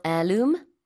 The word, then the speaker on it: heirloom: How do you spell heirloom? – Spell it again. – Yes, one more time. – Heirloom. – 네, 그래서 H 발음하지 않습니다.